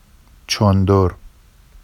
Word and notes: ʧondor: Vafsi

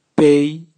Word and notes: bei: Raga

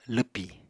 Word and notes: lwpì: apatani

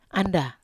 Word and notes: anda: Wamesa

